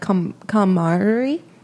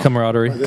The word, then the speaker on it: camaraderie: It builds this, like, camaraderie. Yeah. Am I saying that right? – No. Camaraderie. – Camaraderie, thank you.